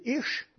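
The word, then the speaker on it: ish (אִישׁ): Le mot homme qu'il y a là, c'est ish que ça se prononce.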